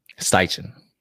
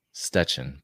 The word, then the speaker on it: Steichen: Steichen. It could be pronounced Stetchen, but we're going to go with Steichen.